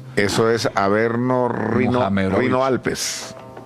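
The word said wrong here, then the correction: Rhône-Alpes: Rone Alpes. – O Rone Alpes, sí. Averno Rone Alpes.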